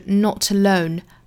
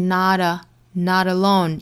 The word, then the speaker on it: not alone: You are not alone，这里面的 you are 同样不缩约的话呢，就是 you are 卷舌音出来，not alone 连读，not alone 这里面的 t 的发音呢，英音的话是 not alone，美音的话是 not alone，有个小弹舌音。